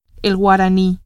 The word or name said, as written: guarani